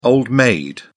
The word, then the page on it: old-maid: British English